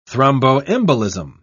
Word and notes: thromboembolism: British English

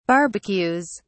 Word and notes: barbecues: British English

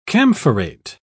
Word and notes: camphorate: British English

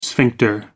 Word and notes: sphincter: American English

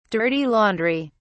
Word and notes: dirty laundry: British English

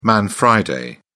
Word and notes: man friday: British English

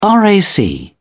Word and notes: rac: British English

